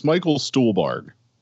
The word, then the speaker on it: Michael Stuhlbarg: Michael Schellberg, Doug Jones, and more. – How do you not know how to say his name still? It's Michael Stuhlbarg.